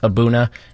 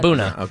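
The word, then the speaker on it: A'bunadh: Cask strength, pronounced Abuna. If you read it, it's impossible to pronounce it. Because it's I did see that on the notes. I'm like, I have no idea how to say that. – Abuna, like Hakuna Matata, Abuna.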